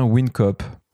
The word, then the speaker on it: WinCoop: Et est-ce que je prononce bien WinCoop? – Oui et oui, c'est bien WinCoop et pas WinCoop, comme le disent la majorité des gens.